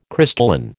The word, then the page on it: crystalline: American English